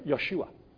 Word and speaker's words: Yeshua: It's like saying Jesus instead of Yeshua, which is the real pronunciation of Jesus.